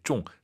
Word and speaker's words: 中: 쫑 아니고 쫑. 맞추는 거니까 사성입니다. 내 컴퓨터가 바이러스에 걸렸어. – 我的电脑中毒了. – 중독이란 한자인데 쫑 사성.